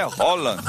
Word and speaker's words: Haaland: Que não é Haaland, viu? Ele ensinou. Foi ele que ensinou. Ele falou: a pronúncia do meu nome é Haaland.